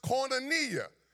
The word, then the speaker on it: koinonia: In other words, that word fellowship in the Greek is pronounced koinonia, K-O-I-N-I-A.